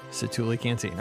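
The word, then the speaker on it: Satu'li Canteen: I always mispronounce it, so I know somebody's yelling at their phone right now. You said it wrong for the 17th time. Santuli Canteen. I got it.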